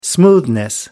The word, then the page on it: smoothness: British English